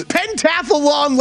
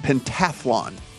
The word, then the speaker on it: pentathlon: It's pentathlon, like marathon. – Yeah, there is no A between the H and the L in this word, and it's just pentathlon.